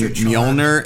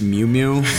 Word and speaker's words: Mjolnir: The fact that she was pronouncing Mjolnir as Mew Mew really irritated me.